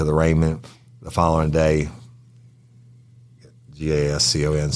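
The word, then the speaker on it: Gascón: Gascon. – I'm doing Rosetta Stone. – You're doing Rosetta Stone, and that. So you have the correct pronunciation, and the rest of America has it wrong? – Okay. I want you to get it. – From now on, I want everybody to say his name correctly, right?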